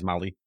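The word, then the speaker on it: Mollé: And it starred Madison Wolf as Barbara and Zoe Saldana as the school counselor, Mrs. Mole, I think is how that is said. It's M-O-L-L-E. – Mole. I always say Molly, but I'm not sure. – Molly? Okay, yeah. – That's how I pronounce it, but I've not watched it, so it's in my head. Yeah. It's Molly.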